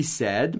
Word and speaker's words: said: O passado de say, S-A-Y, é said, S-A-I-D. Eu não sei porque tem a pronúncia said. Said. Como se fosse um E no meio, né? Mas escreve com A, I.